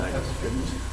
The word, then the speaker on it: Antiochus Epiphanes: How do you say that guy's name? – Antiochus Epiphany. – Antiochus. – Like an epiphany. Mm-hmm. – That's not Antiochus. Epiphanes.